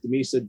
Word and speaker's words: Dimisa: My full name is Dimisa, D-I-M-I-S-A.